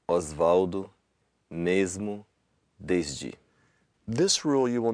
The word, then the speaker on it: mesmo: So rule number four is, if you have an S at the end of a syllable before a voiced consonant, it'll sound like a Z. And so a couple of examples here, would be words like? – Lisboa, Osvaldo, mesmo, desde.